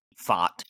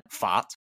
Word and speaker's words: Pfaadt: but you got P-F-A-A-D-T, FOT. Or something. It sounds like fart, I guess. – FOT. – But like with a British accent, FOT. You got FOT coming in.